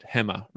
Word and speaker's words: Hema: So if I could ask you again to maybe just give a little bit of your experience about your time in Alibaba's, I think I'm pronouncing it right, Hema, right? – Yep. Yeah, that's exactly right.